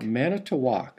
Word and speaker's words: Manitowoc: How do you pronounce Manitowoc? Manitowoc? – Manitowoc. – Manitowoc. – Yeah. – Okay, I've always kind of said it in one fell swoop, Manitowoc.